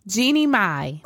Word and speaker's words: Jeannie Mai: You done butchered her name so much. I'm over here. Jeannie Mai. – Oh, Jeannie Mai.